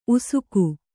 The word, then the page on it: ಉಸಿಕ: usika